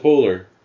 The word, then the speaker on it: Poehler: Yeah, you guys would say Poehler. Yeah, but her, she pronounces it Poehler.